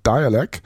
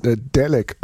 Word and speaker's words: Dalek: Aber es ist nicht von Boards of Canada, sondern aus New Jersey stammen Dialek, geschrieben D-A-L-E-K. Also in Deutschland wird das vermutlich sehr oft falsch als Dalek ausgesprochen. Sie heißen aber Dialek, das Hip-Hop-Underground-Duo, das seit den 90er Jahren auch schon aktiv ist.